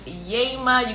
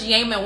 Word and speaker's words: Yema: And so the process, if you're new here, again, my name is Coach Yema. You got to get excited to say my name, like, yay, ma, okay? Coach Yema, what I do is I help LGBTQ+ people who do identify as Christians to make peace with their faith and their sexuality.